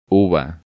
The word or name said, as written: Uva